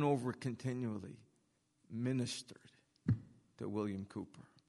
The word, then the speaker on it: Cowper: I remembered that the song was composed by, at least the words were composed by William Cooper. It's C-O-W-P-E-R.